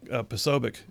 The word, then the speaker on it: Posobiec: So Jack Posobiec, I said his name right. – Yep.